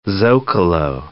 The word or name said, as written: zocalo